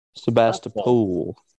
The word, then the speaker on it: Sebastopol: Be sort of weird if Brupolo was in Sebastopol. – Sebastopol? – Is that how it's said? – Sebastopol.